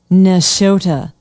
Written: nashotah

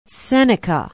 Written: Seneca

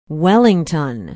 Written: wellington

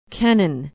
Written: kennan

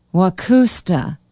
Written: waucousta

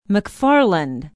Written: mcfarland

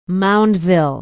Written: moundville

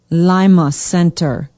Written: Lima Center

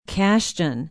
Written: cashton

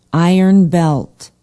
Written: iron belt

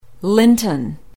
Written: Linton

